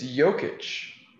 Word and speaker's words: Jokic: What did you just say? – Jokic. – It's Jokic. – It can be pronounced a couple different ways, but no, it really can't.